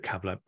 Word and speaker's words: Kabbalah: I pronounce it Kabbalah.